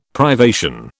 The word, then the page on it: privation: British English